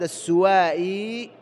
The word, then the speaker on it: السوائي: يزيد بن الأسود هو الصحابي أبو جابر يزيد بن الأسود السوائي بضم السين المهملة مشددة تخفيف الواو وبالمد كما سمعتم النطق، هو من بني سواء ابن عامر ابن صعصعة، ولهذا يقال له أحيانا يقال العامري السوائي العامري، وقيل أيضا في نسبه أنه الخزاعي.